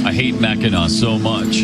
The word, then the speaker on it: Mackinac: When he goes to Mackinac Island? – Oh, no, Mackinac Island. – Mackinac Island. – First of all, yeah. – It's spelled, why do they spell it Mackinac when it's Mackinac?